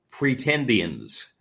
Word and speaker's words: Pretendians: Pretendians.